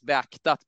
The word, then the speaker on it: beaktat: Are du biktat risken för nyskada? – Yeah, very good. The third word is beaktat, but that's not quite Beaktat.